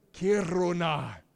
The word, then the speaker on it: Kiruna: Can you say Kiruna with me? Roll your R if you can. Kiruna.